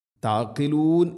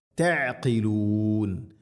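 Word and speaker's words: തഅഖിലൂൻ (تعقلون): ലഅല്ലകും തഅഖിലൂൻ തഅഖിലൂൻ ഇവിടെ തഇന്റെ ഉച്ചാരണം ശ്രദ്ധിക്കണം. താഖിലൂൻ എന്ന് തഇനെ കനപ്പിച്ച് ഉച്ചരിക്കരുത്, താഖിലൂൻ അല്ല, ത ത തി തു എത് തഅഖിലൂൻ തഅഖിലൂൻ.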